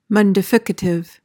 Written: mundificative

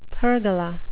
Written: pergola

